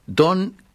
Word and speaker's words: Dawn: Dawn es amanecer, se escribe D-A-W-N, pero no se pronuncia Dawn, porque Dawn es abajo. Dawn, que es D-A, en vez de D-O, D-A-W-N es amanecer y se dice Dawn.